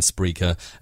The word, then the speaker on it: Spreaker: And we definitely recommend Spreaker. And again, it's spelt like speaker, but with an R in it.